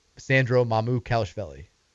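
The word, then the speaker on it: Sandro Mamukelashvili: Sandro Mamu Kalishvili.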